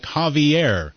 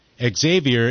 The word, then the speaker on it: Xavier: Ah, someone says it's pronounced like Javier. Pronouncing it Xavier is the stupid American way of doing it. I agree. Yeah, it's got to be Javier. Although Javier is usually spelled with a J.